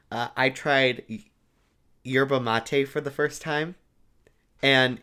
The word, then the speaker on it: yerba mate: Yerba mate.